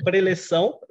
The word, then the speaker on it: preleção: It's a preleção, because, yeah, preleção. We have like these words, really Portuguese, yeah, words.